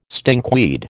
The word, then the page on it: stinkweed: American English